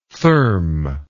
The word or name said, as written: therme